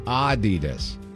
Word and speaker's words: Adidas: It's supposed to be Adidas. Adidas, with a slight emphasis, or emphasis, as you like to say, on the first syllable. Not Adidas, stressing the second.